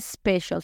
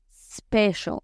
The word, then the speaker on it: special: lo que vamos a hacer es quitarle esa E que nosotros le metemos en una E imaginaria y pronunciarlo spa. No es special, sino special. No Spanish, sino Spanish. La palabra especial se convierte en inglés en special, special.